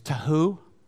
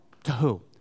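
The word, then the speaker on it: tohu: The word for without form in the Hebrew is tahu, and it means without shape, without distinction, without boundaries, formless, shapeless, without form, tahu.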